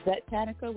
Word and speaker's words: Zetanica: So everything is spelled A-Z-A-Z-L-A. I'm going to repeat. A-Z-A-Z-L-A. And the last name is Zetanica with a Z. So that's Z-E-T-A-N-I-C-A. So I'm going to repeat. – Right. – Azazla Zetanica. A-Z-A-Z-L-A. Z-E-T-A-N-I-C-A.